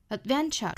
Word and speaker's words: adventure: 间的这个 t 呢不发音 delightful, delightful, 我们可以憋那么一下那和后面的 adventure 做连读要把舌侧音读成 light L 的 l 的形式 delightful adventure, adventure, 这里需要做连读也是辅加元连读了 adventure 重读呢是在第二个音节